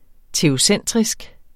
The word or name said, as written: teocentrisk